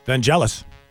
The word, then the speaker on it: Vangelis: Vangelis. – Who's actually, that? With a hard G, believe it or not. – Is it really? – It really is.